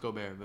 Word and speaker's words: Gobert: I know his name is pronounced Gobert, but it doesn't matter. Gobert.